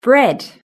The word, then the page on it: bread: British English